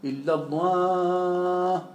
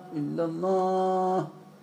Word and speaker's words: illallah: Das war illallah, illallah. Ja, das ist hell, das ist nicht dunkel. Ja. Das, wie ich gesagt habe, das L bei Allah ist ein dunkel ausgesprochenes L.